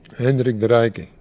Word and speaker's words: Hendrik de Rijke: Yancheng has a history of cooperating with Dutch experts, like water conservancy expert Henry Delik? – Hendrik de Rijke, yeah.